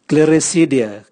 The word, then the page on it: gliricidia: Raga